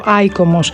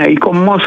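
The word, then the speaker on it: ICOMOS: Αν δεν το γνωρίζετε, αυτό ακριβώς κάνει το ΙΚΟΜΟΣ ή το, δεν ξέρω αν πρέπει να το πω, ΑΙΚΟΜΟΣ. Πρώτα απ' όλα, καλησπέρα σας. – Καλησπέρα σας. Ναι, ΙΚΟΜΟΣ θα το λέγαμε, γιατί έτσι τα προφέρουν όλα με τον γαλλικό τρόπο.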